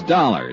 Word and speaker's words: Dollar: It's Dollar, Johnny Dollar. – Dollar, huh? – Yeah, it rhymes with color.